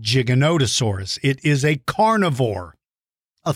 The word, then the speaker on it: Giganotosaurus: But here is the confusion. When you look at the name, you think it says Gigantosaurus. And so did a lot of people in the media. That means from the radio, newspaper, and television. They mispronounced it. They called it Gigantosaurus.